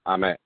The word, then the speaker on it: Ahmed: My name is AJ, but my first name is Ahmed. A-H-M-E-D.